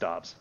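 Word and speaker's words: Doubs: And he came out with a statement today at Packers camp saying, no, it's actually pronounced Dobbs.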